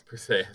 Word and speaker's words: Perseus: Perseus. – Perseus. – That's how you properly pronounce his name.